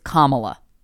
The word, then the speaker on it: Kamala: Also, guys, if you don't know how to pronounce the Joe Biden's vice presidential candidate's first name, it's Kamala.